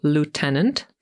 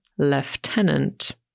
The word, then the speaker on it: lieutenant: On wypowiada to słowo jako lieutenant, ponieważ jest Amerykaninem. Natomiast po brytyjsku spotkacie się z wymową lieutenant. Nie jest to żaden błąd, żadna pomyłka, mimo że w tym słowie nie pojawia się wcale litera F jak Franciszek, to jednak w wymowie właśnie F usłyszymy.